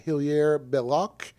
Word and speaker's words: Hilaire Belloc: Matilda is a reference to from a book called The Cautionary Tales for Children by Edward Gorey and Hilaire Belloc, if I'm pronouncing that correctly. – I'm going to say you did pronounce that correctly.